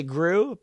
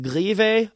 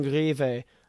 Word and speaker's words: Grewe: Albin, it's spelled G-R-E-W-E. Most people would say Gru, but it's Albin Grive, apparently, the Swedish pronunciation. Let me know how I did on that. Albin Grive.